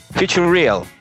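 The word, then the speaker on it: Futureal: Sale Iron Maiden, The Trooper, ahí saludos Lobito, The Number of the Beast, Grandchild, eh, Futurial. ¿Cómo se pronuncia esa canción, weón? Future Real. Sí, es como Future Cereal.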